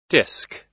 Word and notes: disc: American English

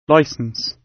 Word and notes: licence: British English

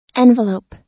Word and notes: envelope: American English

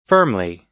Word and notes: firmly: American English

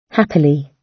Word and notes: happily: British English

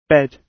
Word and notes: bed: British English

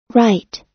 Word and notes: write: American English

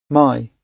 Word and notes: my: British English

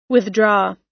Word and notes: withdraw: American English

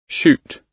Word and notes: shoot: British English

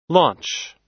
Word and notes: launch: American English

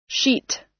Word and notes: sheet: American English

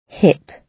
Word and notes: hip: British English

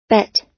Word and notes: but: American English

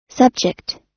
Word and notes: subject: American English

